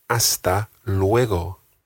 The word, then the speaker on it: hasta luego: The G in hasta luego is a very soft G. It's not luego, it's luego.